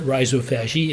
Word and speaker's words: rhizophagy: Now I pronounce it rhizophagy. It's really one word, rhizo, which means root, and phagy, which means eat.